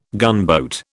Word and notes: gunboat: British English

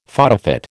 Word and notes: photofit: American English

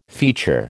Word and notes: feature: American English

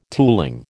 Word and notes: tooling: American English

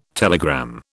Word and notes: telegram: British English

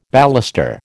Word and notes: baluster: American English

